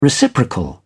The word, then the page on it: reciprocal: British English